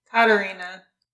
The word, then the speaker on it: Katharina: Also, since you listened to the audiobook, is it Katarina or Katharina? – Katarina.